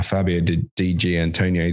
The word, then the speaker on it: Fabio Di Giannantonio: I mean, I want to talk about firstly about uh, Fabio Di Giannantonio. Did I butcher his name? No. No.